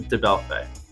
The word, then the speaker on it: DeBellefeuille: Robert DeBellefeuille.